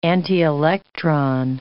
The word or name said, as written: antielectron